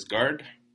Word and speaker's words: Gard: Well, like you said, my name is Gard, like in a Coast Guard. It's not that difficult, really. Spelled the same way without the U.